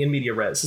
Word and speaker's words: in media res: Much like the Metal Gear novelization, it'll be in media res.